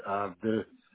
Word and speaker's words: Ardu: A-R-D-U, Ardu.